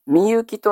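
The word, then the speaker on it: 深雪: 54 番、みゆきとみゆき。深い雪と書いて、みゆきと発音します。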